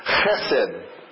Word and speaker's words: chesed: The Hebrew equivalent of this word is a word chesed.